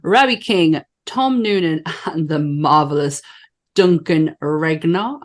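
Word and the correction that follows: Regehr: I hope I'm saying that right. – I think it's Rager. Yeah.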